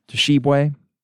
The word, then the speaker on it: Tshiebwe: Oscar Tshiebwe, or it's just Tshiebwe, might be a silent T.